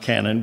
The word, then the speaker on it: kanon: It actually means, it's from the Greek word spelled K-A-N-O-N, which is pronounced canon, which means rule or measuring stick.